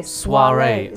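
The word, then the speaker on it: soirée: So soiree is spelled S-O-I-R-E-E with a 第二个 accent. – Soiree. – E-E. And the first E has the upwards. So soiree, it's a French word.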